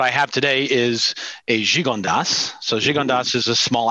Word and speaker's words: Gigondas: And this wine here is Gigondas Pas de Leg, is how it's pronounced.